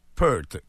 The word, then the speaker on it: Peart: A, Neil Peart, comme une pearl, comme une perle. – OK, Neil Peart. Neil Peart.